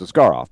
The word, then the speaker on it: Askarov: He went to San Jose and he said, "Yeah, call me Askarov." Where after we were with him for 3 years in Milwaukee, and we went over it, how you say it really in Russian, which is Askarov.